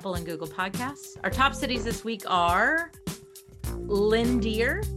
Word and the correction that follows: Leander: Is that how you say it? Landier? – Maybe Leander? – Leander. – Leander. – There's Leander. Leander, Texas.